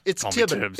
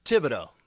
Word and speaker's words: Thibodeau: No, it's Thibodeau. It's Thibodeau. Thibodeau. – Okay. Thibodeau, silent H.